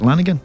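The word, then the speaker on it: Lanegan: Is it Lanigan? – Yeah, I believe so.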